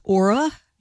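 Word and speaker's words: Oura: It's Aura. They make those smart rings that just about everybody is wearing these days. They've filed for an initial public offering showing soaring revenue, but also rising losses. But Aura says it's sold about 3.6 million smart rings in the last year.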